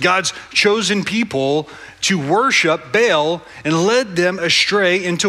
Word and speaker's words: Baal: Now, my old seminary professors would be a little bit frustrated with me for pronouncing Baal's name as Baal. His name is really more pronounced something more like Baal.